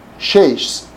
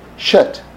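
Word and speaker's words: Shet (שת): In Hebrew you say Shesh. I have trouble pronouncing that. It's Shin, Tav. Shet. Okay, so we'll say it like that.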